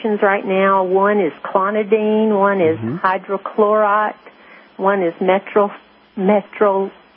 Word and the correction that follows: metoprolol: I can't pronounce it. Metoprolol. – It's called metoprolol.